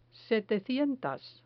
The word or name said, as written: setecientas